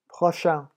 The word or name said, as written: prochain